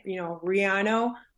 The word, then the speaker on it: Riano: It's kind of, it's one of those things that it always puzzles me a little bit because I'm actually born and raised in Colombia, and that's the perfect way of pronouncing my last name. But living in Central Florida since being 10 years old, it was always a challenge. With the language and learning how to, get used to people pronouncing my last name properly. So I'm used to the American way of saying it, Riano, but man, you nailed it.